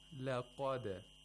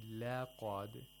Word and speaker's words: laqad: For example, if you say laqad, let you open your mouth too much, that's incorrect. Laqad, laqad.